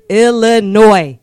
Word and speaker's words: Illinois: S in Illinois is silent for everybody that does not know. – Well, y'all, this is a PSA, a public service announcement. – Yeah, these are intelligent people on the show. I agree. – Illinois is silent. It is pronounced Illinois.